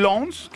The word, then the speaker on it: Lens: Entonces, no es el Lens, es el Lens. – Lens de Francia. – Sí. El Lens. – Sí. – El Lens. Porque la S al final tampoco se debería pronunciar.